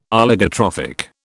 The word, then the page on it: Oligotrophic: British English